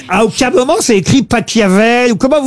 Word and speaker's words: Pachelbel: Ça s'écrit P-A-C-H-E-L-B-E-L. – Mais il adorait l'Italie. – Alors, par contre, Laurent, s'il est allemand. – Cannelloni, c'est Pachelbel. – S'il est allemand, ça se dit Pachelbel.